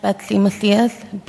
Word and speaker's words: Betsy Macias: My name is Betsy Macias, B-E-T-S-Y-M-A-C-I-A-S.